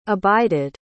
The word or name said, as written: abided